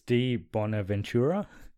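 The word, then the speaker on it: di Bonaventura: I'm going to butcher this. D. Bonaventura? – Oh, yeah.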